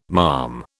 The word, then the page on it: malm: British English